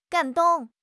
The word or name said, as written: gan3 dong4